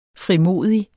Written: frimodig

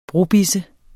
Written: brobisse

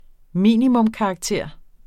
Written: minimumskarakter

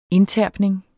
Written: indterpning

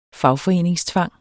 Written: fagforeningstvang

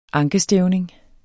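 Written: ankestævning

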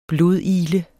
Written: blodigle

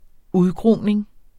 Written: udgroning

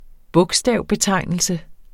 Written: bogstavbetegnelse

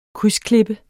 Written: krydsklippe

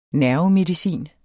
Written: nervemedicin

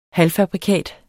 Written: halvfabrikat

